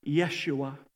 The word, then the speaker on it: Yeshua: You see, the name Jesus is really the Greek form of a Hebrew name, Joshua. Or as it's pronounced in Hebrew, Yeshua.